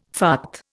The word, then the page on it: fart: Indian